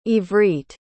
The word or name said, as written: Ivrit